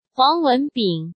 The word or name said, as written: Huang Wenbing